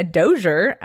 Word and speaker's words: Dozier: So I recently found this perfume brand, Dossier, which is spelled just like my last name, a Dozier, but they say it very more elegant because they're classy.